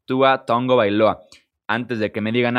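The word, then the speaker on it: Tagovailoa: la pronunciación correcta es esa, Ton Gobailoa. Se podrá escribir Tagobailoa, pero se pronuncia Tongo Bailoa.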